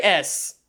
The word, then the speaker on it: es: E-S. ¿Cómo se pronuncia is? Es. Es. Not S.